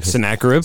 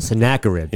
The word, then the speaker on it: Sennacherib: And that was, I always say his name wrong. Tell me again. – Sennacherib? – Sennacherib, yeah. Yes.